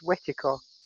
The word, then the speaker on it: wetiko: which they termed as wetiko. Mm. I think I'm pronouncing it correctly. It's spelled W-E-T-I-K-O.